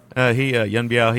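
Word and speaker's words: Yuen Biao: Yun Biao? Is that how you say his name? – Yep, Yun Biao, yep. – Yun Biao, he he climbs up into a tree and he ties a vine around his ankle and decides to jump down on somebody.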